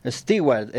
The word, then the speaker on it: Stewart: Y en el 41, perdón, yo no soy muy bueno con el inglés, con la pronunciación, Stewart, ¿no? – Sí, Stewart. – Stewart, empresaria y presentadora estadounidense referente mundial del estilo de vida y la decoración.